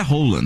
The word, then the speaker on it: Haaland: O Haaland. – Nossa, gente. – Ele é altão, né? – Que é aquilo, gente? Hein? Branquinho. – A pronúncia oficial do nome dele é Rola, né? – É, então, fiquei sabendo.